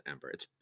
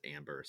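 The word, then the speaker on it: Æmber: I pronounce it Ember. It's spelled AEMBER, and some people pronounce it Amber, some people pronounce it Ember. It's the same thing. – I'm in house Amber. – I'm definitely Amber. – I mean, if you think about how ether or aether is pronounced, it could be Amber.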